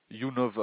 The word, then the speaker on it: Younov: Younov. Alors comme ça se prononce, monsieur. Y-O-U. – Y-O-U. – NOV. – N-O-V.